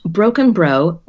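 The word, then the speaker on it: Brockenbrough: It's pronounced broken bro, rhymes with broken toe.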